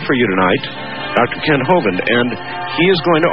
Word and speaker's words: Hovind: It reads, Dear Art, I was given a tape by Dr. Kent Hovind, and that is how it is pronounced, incidentally, Hovind.